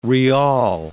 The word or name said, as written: rial